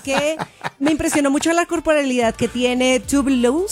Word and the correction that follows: Tove Lo: Tove Lo, Dios mío, siempre me cuesta mucho la pronunciación correcta de su nombre ya, pero todos la conocemos como Tove Lo, y de verdad se mueve súper sensual.